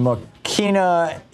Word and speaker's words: Mokena: And welcome back, John in Mokena, Illinois.